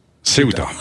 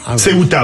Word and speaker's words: Ceuta: Sébastien Chenu a-t-il dit, ce tas, c'est ce qui se passera en France demain. – Ceuta. – Ceuta. Ah, ouais. Alors, je voulais prononcer à la française.